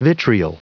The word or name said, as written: vitriol